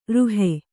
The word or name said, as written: ರುಹೆ